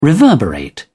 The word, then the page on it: reverberate: British English